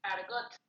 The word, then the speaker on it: Argotte: Argot.